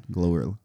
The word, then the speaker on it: GloRilla: But Glorilla, I'm saying it right? – Yeah, Glorilla.